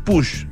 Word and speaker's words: Puig: Se les llama narices, un perfumista, el perfumista senior de la empresa Push, que se escribe Puig, entiendo, pero se escribe Puig, pero entiendo que se dice Push. – Claro, uno lo dice Puig cuando...